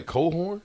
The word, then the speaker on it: Cochran: Did I say Cohorn? – Yeah, that's how it's pronounced. – Does it spell like that? – It is. – C-O-C-H-R-A-N.